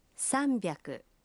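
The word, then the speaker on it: «Самбяку» (さんびゃく): 300 произносится… «Самбяку».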